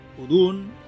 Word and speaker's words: Udûn: Udun, el títol, està ben pronunciat, ho diu, ho diu l'especialista dels marcianos, així que està ben dit.